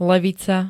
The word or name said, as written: levica